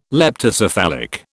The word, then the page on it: leptocephalic: British English